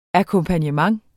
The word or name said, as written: akkompagnement